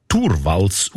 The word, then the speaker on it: Torvalds: Der Linus Torvalds, eigentlich Torvalds ausgesprochen, dazumal Informatikstudent Helsinki, er hat die wertvollste Software von der Welt namens Linux einfach verschenkt.